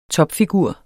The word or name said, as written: TOPFIGURER